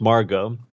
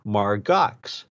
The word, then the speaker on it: Margaux: Her name is Margot. Mm-hmm. And it's got a French pronunciation and spelling, M-A-R-G-A-U-X, much like Chateau Margaux, the expensive Burgundy. So anyway, everybody pronounces her name Margox.